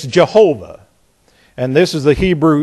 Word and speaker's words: Jehovah: The proper way is Jehovah.